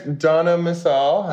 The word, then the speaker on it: Donna Missal: Well, then there's Donna Missal.